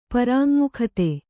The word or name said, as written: ಪರಾಙ್ಮುಖತೆ